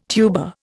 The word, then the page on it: tuba: Indian